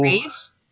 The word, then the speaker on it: Ra's al Ghul: Raish or Ra's. – Ra's. – Ra's al Ghul. – Ra's al Ghul. Yeah, how did they say it in the Dark Knight in the Batman trilogy? – There's also Race.